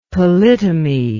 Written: polytomy

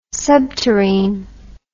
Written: subterrene

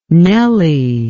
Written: Nelly

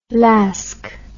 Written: lasque

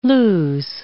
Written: lose

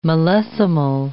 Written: millesimal

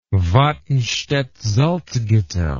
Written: Watenstedt-Salzgitter

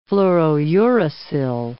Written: fluorouracil

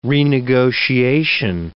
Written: renegotiation

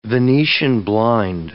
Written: venetian blind

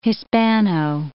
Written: Hispano

